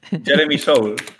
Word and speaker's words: Soule: Jeremy Soul, tal cual, sí.